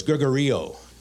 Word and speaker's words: Gregorio: It's Greg Oreo, but we're just going to put the emphasis on the E of Oreo. So it's Gregorio.